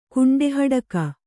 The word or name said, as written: ಕುಂಡೆಹಡಕ